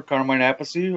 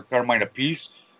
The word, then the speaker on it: Carmine Appice: King Kobra, fronted by the world's greatest rock drummer, Carmine Appice, or Carmine Appice. Really depends on what day he wants to say his name. I've heard it both ways.